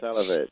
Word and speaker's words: Salewicz: I'm pronouncing your name completely incorrectly. Please do let me know. – Salewicz.